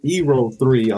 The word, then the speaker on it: Ero3: Well, my name is Ero3.